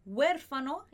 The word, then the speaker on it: huérfano: Se quedó huérfano, esse H não vai ter som, então vai ser huérfano. Se quedó huérfano. E aí a gente tem en un accidente, se quedó huérfano en un accidente.